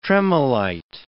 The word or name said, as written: tremolite